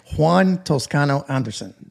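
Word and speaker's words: Juan Toscano-Anderson: Juan Toscano Anderson.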